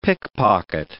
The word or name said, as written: pickpocket